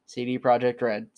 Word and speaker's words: CD Projekt Red: He had sold his soul to CG Projekt Red. CD Projekt Red. CG Projekt Red. There we go. I can say it like a human.